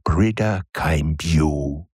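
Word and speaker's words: Brìghde Chaimbeul: Geblasen hier von der schottischen, ja, das gibt's, experimentellen Dudelsackspielerin Brida Kimbue. Die man völlig anders schreibt, als man sie ausspricht, sagt mir die Pronunciation KI. Brida Kimbue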